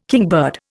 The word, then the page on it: kingbird: Indian